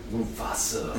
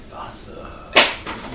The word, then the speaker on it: Mufasa: And then Mufasa. No, we don't say it like that. We say it like this. Mufasa.